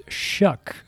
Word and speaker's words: Choque: C-H-O-Q-U-E, pronounced Shuck. It's a French-Belgium name.